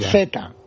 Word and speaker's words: Zvyagintsev: Tivaginskev. – Es bueno, esa es una película sobre la Rusia de ahora mismo. – Es Yajinstev. ¿Cómo se pronuncia eso? – Creo que es buenísima. – Zvyagintsev.